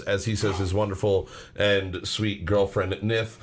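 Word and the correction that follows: Niamh: Neef, sorry, Neef. It's pronounced Neef.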